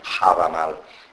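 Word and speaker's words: Hávamál: Quindi un dono è ciò che questo pezzo dell'Edda, questo pezzo dello Havamal, Havamal che con l'H davanti, Havamal, per il resto è come lo pronuncio.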